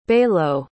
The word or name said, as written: balow